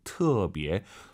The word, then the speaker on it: 特别: 무지무지, 特别 발음하실 때 터 아니고 트로 시작해야 돼요. 特别.